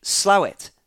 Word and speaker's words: Slaithwaite: It is written SLAITHWAITE. And Sam says, further to my previous email, I would like to confirm that the pronunciation of said town is indeed Slowit. Spelt Slawit by the locals.